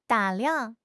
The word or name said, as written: 打量